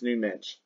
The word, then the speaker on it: Nuno Mendes: Also known as Nuno Mendes, the Portuguese pronunciation is Nuno Mendes.